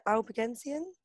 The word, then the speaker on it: Albigensian: But there was one called the Albigensian. Did I get that right, Sophie? – Yes.